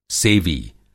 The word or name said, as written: सेवी